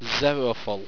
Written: xeroful

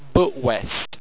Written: butwezt

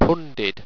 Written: punded

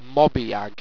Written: mobiag

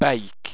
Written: beyq

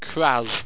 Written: craz

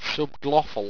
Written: subglofl

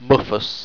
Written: mmuffous

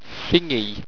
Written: phingy

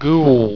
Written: gguwl

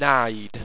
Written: pnide

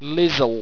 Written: lizzle

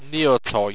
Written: neotoj

